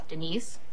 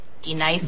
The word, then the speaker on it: D-Nice: Say your name right, right now. – Denise? – Say it right. – Denise. – Correctly. – Denise. – Right. – Denise. – Right. – D-Nice. – That's better.